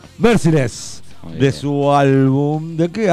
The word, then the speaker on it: Merciless: ¿Es Merikles o cómo carajo se dice, Brancati? – Si se escribe, no, ahí dice otra cosa, dice Merciless. – Merciless, me leí mal.